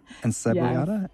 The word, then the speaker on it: encebollada: Carne frita, and let me see if I can pronounce this correctly. Encebollada. Encebollada? – Yes. – Encebollada. – Yes, you said it right. That's perfect.